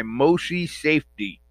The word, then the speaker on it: Moshe Safdie: Anyway, so Habitat 67 was designed by Moshe Safdie.